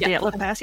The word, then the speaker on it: Dyatlov Pass: It's so creepy because I always had a fascination around the Dyatlov Pass. I don't know if I'm pronouncing it. The Dyatlov Pass, yep.